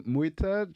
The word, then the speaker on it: Mwita: First comment on my name, Muita, just yeah.